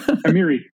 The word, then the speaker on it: Amiri: Also, I've never heard it pronounced, so I'm guessing there. – Yeah, no, you got it right. Amiri.